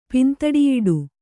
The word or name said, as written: ಪಿಂದಡಿಯಿಡು